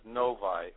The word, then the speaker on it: Novi: And it's in, now how do you pronounce the name? Is Navi or Novi? – They actually pronounce it Novi over here.